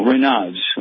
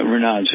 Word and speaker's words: Raynaud's: R-A-Y-N-A-U-D, and then there's P-H-E-N-O-M-E-N-O-N. – Oh, Raynaud's, okay. That's what it's called. Yeah, Raynaud's.